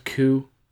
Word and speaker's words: coup: We haven't really talked much about Venezuela since the failed coup. Did I say it right that time? Coup? – Yes, dear.